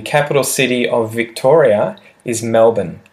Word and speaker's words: Melbourne: And again, sort of like Canberra, this one has a spelling that often tricks people because it's spelt M E L B O U R N E. M E L B O U R N E. A lot of people, especially Americans, will often try and say that R really um, strongly at the end of the word. They will really They'll really try and pronounce that R. However, the average Australian would call the place Melbourne.